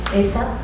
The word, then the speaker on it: Asa: So it's not only God who's associated with perfection, but lots of people, like Noah and Asa, did I say that right? – Asa, Hezekiah, Job, a lot of sacrificial animals are perfect.